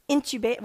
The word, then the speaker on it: intubated: For 3 days, we were just like, and it, you know, he was incubated or whatever you call that. I never can say it right, but intubated. Well, how do you say it? Intubated, thank you.